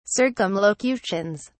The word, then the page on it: circumlocutions: British English